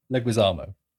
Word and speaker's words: Leguizamo: Leguizamo, language less Leguizamo. – John Langwistein. – John Leguizamo.